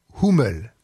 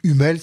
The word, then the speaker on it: Hummel: Et donc j'ai choisi de vous parler d'un compositeur assez peu connu, qui pourtant me semble-t-il très intéressant, et qui s'appelle Johann Nepomuk Hummel, ou Hummel si vous le prononcez à la française.